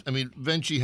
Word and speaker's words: Venchi: Oh, it's key. Chi. Venchi, not Venchi. – Venchi, we've been saying it wrong.